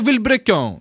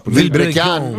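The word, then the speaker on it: Vilebrequin: Διαβάζεται Βιλμπρεκιόν. Είναι αυτό με τα μαγιό και τα λοιπά. – Βιλμπρεκιόν. – Βιλμπρεκιόν.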